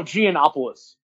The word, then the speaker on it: Giannopoulos: Oh, Giannopoulos.